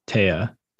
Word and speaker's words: Teatora: Okay, it looks like T, like British T, and then T-O-R-A. It's Teatora.